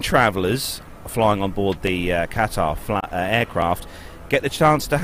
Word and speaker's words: Qatar: So at number two, it's either Qatar. – It's Qatar.